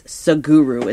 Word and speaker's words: Sadhguru: And then, but you can hashtag how he, in most places, hashtag S-A-D-H-G-U-R-U. And that's Saguru is how you pronounce that.